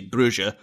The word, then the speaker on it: Brugge: My understanding is that this could be completely wrong, but because of the part of Belgium that they're in, they tend to lean more towards the kind of Dutch side of pronunciation, whereas a lot of the rest of Belgium is the French side of pronunciation, which we would say Bruges, but the Dutch kind of influence would have it as Brugge.